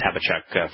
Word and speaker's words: Habakkuk: And what is the, when Yahweh and Chabaouk, which it means embrace this, Habakkuk, for those that prefer the more Anglican pronunciation,